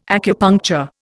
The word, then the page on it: acupuncture: Indian